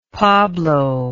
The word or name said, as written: pablo